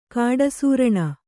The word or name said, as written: ಕಾಡಸೂರಣ